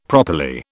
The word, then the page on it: properly: British English